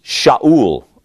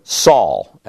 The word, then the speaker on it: Saul: And he has a son, and his son's name is Shaul, or Saul, as it's pronounced in English.